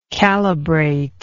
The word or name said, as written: calibrater